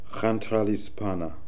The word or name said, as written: Hentralispana